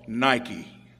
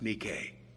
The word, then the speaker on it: Nike: There's a sports brand called Nike that everybody pronounces wrong. It's Nike.